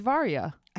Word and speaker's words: Varya: It's Varya. – I know. I don't know why I'm not good at it. – Think of like var and then ya.